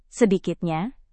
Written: sedikitnya

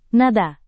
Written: nada

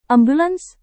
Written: ambulans

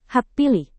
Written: hak pilih

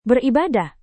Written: beribadah

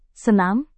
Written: senam

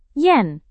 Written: yen

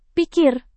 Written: pikir

